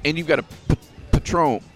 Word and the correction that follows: Patreon: Am I saying it right? – I got some Patron. – Oh, yeah, Patreon.